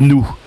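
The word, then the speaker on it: noue: Avec le couvreur, on a parlé de toi et on a parlé de nous. La nous, ça s'écrit N-O-U-E, c'est donc le contact entre une toiture et son oblique, et un mur et sa verticale.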